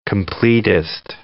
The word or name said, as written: completist